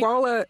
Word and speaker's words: Dwolla: Dwolla.